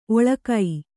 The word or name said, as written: ಓಲಗ